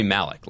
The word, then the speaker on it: Malek: It's Rami Malek, like Terrence Malek.